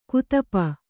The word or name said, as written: ಗದಪು